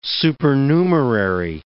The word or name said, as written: supernumerary